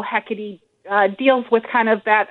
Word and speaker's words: Hekate: Yeah, it's spelled H-E-K-A-T-E. Hecate. – Correct. – Looks like yeah. – Hecate.